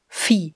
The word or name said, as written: Vieh